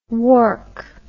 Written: wark

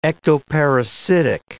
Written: ectoparasitic

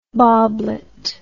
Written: boblet

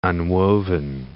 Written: unwoven

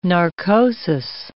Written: narcosis

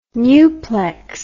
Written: nuplex

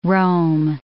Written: roam

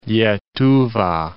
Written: Lietuva

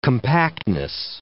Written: compactness

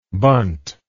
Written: bunted

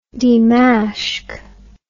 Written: Dimashq

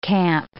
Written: camp

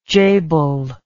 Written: jabal

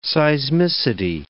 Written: seismicity

